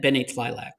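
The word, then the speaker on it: Tleilax: Bene Tlilax. That's how Kyle pronounced it in the first movie. Bene Tlilax.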